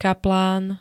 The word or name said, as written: kaplán